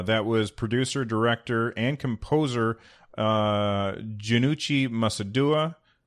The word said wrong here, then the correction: Masuda: Uh, Masuda, sorry, I said his name wrong.